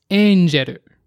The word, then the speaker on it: Angeru: An example would be if a child was given the name of Tenshi, which means angel, but it's pronounced as Angeru, like the English word angel.